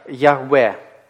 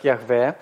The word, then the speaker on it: Яхве: Первоначально оно, скорее всего, произносилось как Яхве.